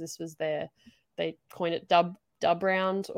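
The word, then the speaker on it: DUB RND: I say Dubrund because if you go and look on Melbourne Victory's social media, they have stylized it to be D-U-B and then R-N-D.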